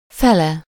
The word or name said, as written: half